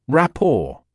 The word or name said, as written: rapport